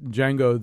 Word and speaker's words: Django: but when they do the thing about Django, the D is silent, yeah.